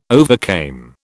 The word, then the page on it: overcame: British English